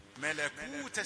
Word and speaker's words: ملكوت: ملكو كو لا، لا نقول كو. ملكوت السماوات والأرض.